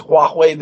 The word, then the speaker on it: Huawei: It begins with an H, but it's Huawei, the Chinese company that makes phone and routing equipment.